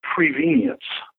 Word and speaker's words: prevenience: The word is prevenience. It rhymes with convenient.